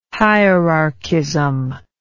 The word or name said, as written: hierarchism